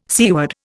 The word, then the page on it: seaward: Indian